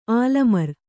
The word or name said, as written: ಆಲಮರು